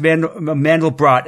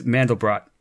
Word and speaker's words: Mandelbrot: It looks like in the language, it would be either Mandelbro or Mandelbra. So it's like Mandelbrot, but the T trails off.